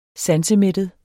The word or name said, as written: sansemættet